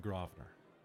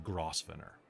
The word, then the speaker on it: Grosvenor: Soon you find yourself standing in front of an ostentatious, gorgeous hotel, the Grosvenor. But for you, it's the Grosvenor, because there's no way that you would get that word from that. – Grosvenor. – The buggers pronounce it Grosvenor.